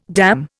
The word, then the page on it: damn: Indian